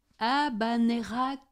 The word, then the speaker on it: Habanera: Habanera Carmen. De Carmen, comme tu as Habanera dit. Carmen. Il a bien prononcé, Monique.